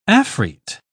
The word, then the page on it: afrit: British English